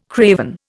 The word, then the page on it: craven: Indian